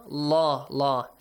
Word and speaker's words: Allah: So, if the letter before the word Allah, it carries a fatha or a dhamma on the top, the word Allah is pronounced how? – Heavily. – Inna Allah, Allah. What if it carries a kasra below it? – Lightly.